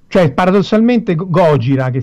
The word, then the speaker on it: Gojira: E, e, quindi fondamentalmente da un nome che avevano creato da, in inglese Godzilla dice no questa cosa non si può pronunciare nella, nella televisione giapponese lo chiamiamo Gojira e